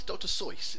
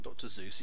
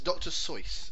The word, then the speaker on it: Seuss: But it's Dr. Seuss. It's not Dr. Zeus.